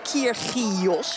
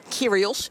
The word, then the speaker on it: Kyrgios: Kyrgios eigenlijk, maar Kyrgios spreek je uit in Australië.